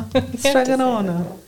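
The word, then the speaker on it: Strega Nona: You say it like an Italian grandma. Stregonona.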